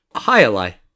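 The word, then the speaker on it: jai alai: Hi-a-lie.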